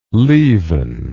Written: leven

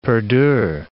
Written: perdure

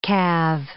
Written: calve

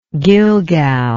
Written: Gilgal

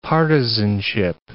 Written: partisanship

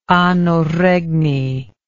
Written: anno regni

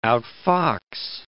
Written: outfox

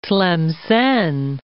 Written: Tlemcen

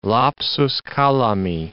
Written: lapsus calami

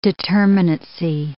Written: determinacy

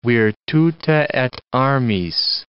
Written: virtute et armis

